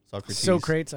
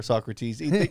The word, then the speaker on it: Socrates: Socrates, I love that. – Or Socrates. I heard people pronounce it either way. It doesn't matter.